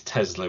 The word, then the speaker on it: Tesla: It is Tesla with a Z. Well, it's not spelt with a Z, but it's pronounced.